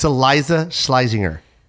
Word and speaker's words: Iliza Shlesinger: It's Iliza Shlesinger. Did I say that right? – That didn't sound right at all. – It is. – That was right? – Yeah.